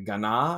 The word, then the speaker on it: Gaana: You know, they give you different destinations and a new one came up and it's spelled G-A-A-N-A. It was like Ghana, I think is how it's pronounced.